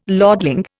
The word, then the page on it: lordling: Indian